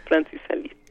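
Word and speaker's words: Francis Alÿs: y, en esta ocasión hay un video de Francis Alice, ¿lo dije bien? Sí, Francis Alice.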